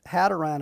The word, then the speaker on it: Hadrian: The emperor was called Hadrian, as I pronounce it. H-A-D-R-I-A-N. – Hadrian. – Hadrian? I like the had.